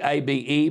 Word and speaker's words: Abe: Abe, A B E, pronounced Abe, was someone who was respected right across the world.